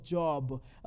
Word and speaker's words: Job: And when you go, when you get to the book of Job, that's how I pronounce it.